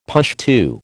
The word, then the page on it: Pushtu: American English